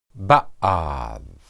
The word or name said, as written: baad